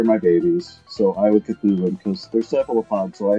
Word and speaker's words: Cthulhu: How do you pronounce it then? – Cthulhu. – Ah, well done.